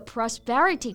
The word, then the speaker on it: prosperity: 注意一下这个发音啊，重音是在第二个音节上。嗯，那为什么这个 prosperity 可以用呢？我们先来看一下它的英文释义。 So prosperity is the state of being successful, especially in making money。